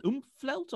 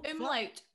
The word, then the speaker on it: umlaut: Is it called an oomphlaut or umlaut? Umlaut.